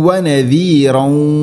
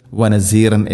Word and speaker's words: വനദീറൻ: പ്രത്യേകം ശ്രദ്ധിക്കേണ്ട വനദീറൻ ദാല് അതിൻ്റെ ഉച്ചാരണം വനസീർ എന്ന് വനസീറം ഇങ്ങനെ ഓതിപ്പോകരുത്. സാധാരണക്കാർ മീൻസ് തജ്വീദ് നിയമങ്ങളോ അക്ഷരങ്ങളോ ഉച്ചാരണമൊന്നും അറിയാത്ത ആളുകൾ പ്രത്യേകം ശ്രദ്ധിക്കുക, വനസീറൻ എന്ന് പറയരുത്.